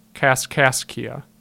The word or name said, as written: Kaskaskia